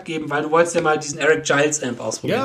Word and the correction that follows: Gales: Gales, Gales.